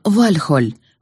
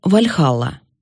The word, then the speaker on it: Вальхалла: Название дворца Одина «Вальхоль» мы будем произносить традиционно — «Вальхалла». Ударение всегда, без исключения, падает на первый слог.